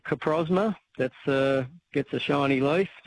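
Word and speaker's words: Coprosma: C-O-P. – Yeah. – P-R-O-S-M-A, Caprosma. – Caprosma.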